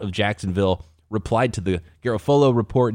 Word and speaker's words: Garofalo: Or Garofalo, I believe, is how he pronounces it. – Yeah, correct.